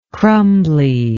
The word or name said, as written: crumbly